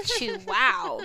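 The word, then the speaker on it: Chuao: It's like Chew Wow because it's Chuaouyeou. – Exactly. – I'm just all the vowels. – Pretty much. But they're like, it's pronounced Chew Wow.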